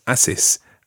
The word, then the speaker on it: haces: that would be said as haces, haces. Now, if you think about that word, haces, que haces, in actual fact, there's a silent H at the beginning of that word. Haces is spelled H-A-C-E-S, and it's impossible to know that, other than recognising that word that you may have come across before.